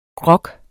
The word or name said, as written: grog